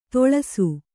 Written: ತೊಳಸು

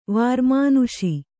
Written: ವಾರ್ಮಾನುಷಿ

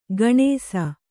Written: ಗಣೇಸ

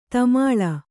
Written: ಧೂಮಲ

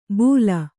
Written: ಬೂಲ

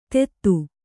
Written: ತೆತ್ತು

